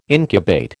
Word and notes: incubate: American English

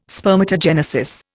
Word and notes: spermatogenesis: Indian

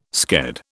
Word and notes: sked: British English

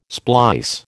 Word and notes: splice: American English

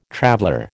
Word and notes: traveller: American English

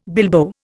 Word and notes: bilbo: Indian

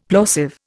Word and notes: plosive: Indian

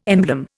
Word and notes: emblem: Indian

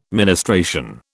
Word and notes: ministration: British English

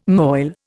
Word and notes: moil: Indian

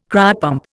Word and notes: cryopump: Indian

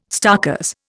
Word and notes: starkers: Indian

